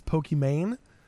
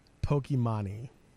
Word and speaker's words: Pokimane: Is it actually pronounced that way? Because her name is Imani. – I mean, I have always heard it as Pokimane, but not Pokimani. – I thought it was Poke and then Imani, because her name is Imani.